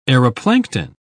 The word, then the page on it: aeroplankton: British English